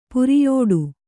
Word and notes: ಪುರಿಯೋಡು: kannada

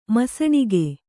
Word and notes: ಮುಸಿನಗು: Kannada

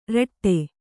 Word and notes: ರೀಠೆ: Kannada

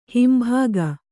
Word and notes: ಹಿಂಭಾಗ: himbhāga; Kannada

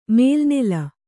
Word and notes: ಮೇಲೆನೆಲ್ಲಿ: mēle nelli

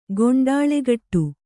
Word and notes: ಗೊಂಡಾಳೆಗಟ್ಟು: Kannada

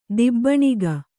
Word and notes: ದಿಬ್ಬಣಿಗ: kannada

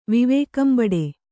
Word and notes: ವಿವೇಕಂಬಡೆ: kannada

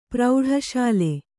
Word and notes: ಪ್ರೌಢಶಾಲೆ: kannada

